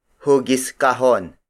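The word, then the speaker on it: hugis kahon: The two modern Tagalog variations are cube, cube, cube, which is spelled as K-Y-U-B. Also, hugiskahon, hugiskahon, hugiskahon. Hugiskahon is spelled as H-U-G-I-S hyphen K-A-H-O-N.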